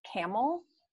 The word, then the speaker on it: Campbell: And so we have a fairly large Greek speaking population, especially in Camel schools, which is spelled actually like Campbell, like Campbell soups, but it's pronounced Camel.